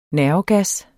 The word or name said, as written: nervegas